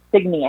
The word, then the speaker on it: Signe: Signe, I think is how you pronounce it.